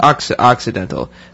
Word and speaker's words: Occidental: Occidental? I think that's pronounced. – Occidental? – Occidental.